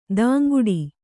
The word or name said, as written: ಧಿಂಕಿಡು